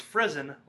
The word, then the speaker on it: Friesen: It's Jenny Friesen. She goes, Friesen like prison.